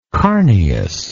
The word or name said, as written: carneous